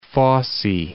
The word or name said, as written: Fossey